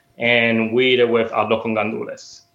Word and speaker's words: arroz con gandules: Another item on the menu I wasn't familiar with, and I'm probably going to butcher the pronunciation, the arroz con gandules. – Yeah, that's perfect. You pronounced that perfect. – All right. – Yeah. So arroz con gandules, traditionally, it's a Puerto Rican rice dish that we would pork in Christmas.